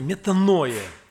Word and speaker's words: метаноя: Оно имеет такое произношение – метаноя.